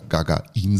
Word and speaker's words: Gagarin: Ne, die Gagarin ja, sagst du? Ich sag Gagarin, ich glaube die erste Silbe. Also mir haben die Kollegen beim MDR sagen mir immer im Russischen die erste Silbe betonen.